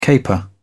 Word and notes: caper: British English

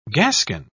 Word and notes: gascon: British English

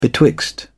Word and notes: betwixt: British English

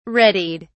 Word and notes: readied: British English

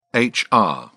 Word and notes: hr: British English

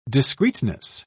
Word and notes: discreteness: British English

